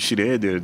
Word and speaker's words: Schroeder: Pode me chamar de, de, de Schroeder, de Max, Fica mais fácil, Max.